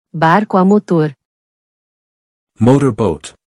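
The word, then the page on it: Barco a motor: Portuguese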